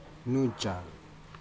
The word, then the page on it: nujaca: Aneityum